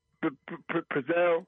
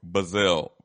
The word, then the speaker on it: Bezzel: Bezzel? – Bezzel. B-E-Z-Z. – Oh, Bezzel.